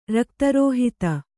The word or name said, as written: ರಕ್ತರೋಹಿತ